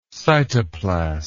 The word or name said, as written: cytoplastic